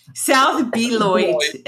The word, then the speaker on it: Beloit: They have two locations, one in South Beloit. Did I say that right? And one in Belvedere. – South Beloit and Belvedere. It's French. – Beloit, like a beignet.